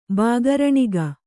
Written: ಬಾಗರಣಿಗ

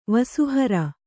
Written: ವಸುಹರ

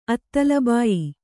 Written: ಅತ್ತಲಬಾಯಿ

